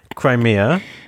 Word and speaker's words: Crimea: And she was like, well, Crimea River, Crimea.